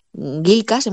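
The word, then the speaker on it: Ghikas: Y llega, cuando acaba sus vacaciones y llega a la comisaría, pues se da cuenta de que, de que su jefe, Gilcas, hemos dicho que lo vamos a pronunciar así, eh, se ha jubilado se ha jubilado y le propone que él sea temporalmente su sustituto.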